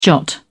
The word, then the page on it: jot: British English